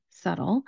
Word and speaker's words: subtle: The word is spelled S-U-B-T-L-E. If you look at it, it should be something like subtile. Again, when we look at the spelling, we're going to take out a letter and we're not going to pronounce it. In this case, the letter is B.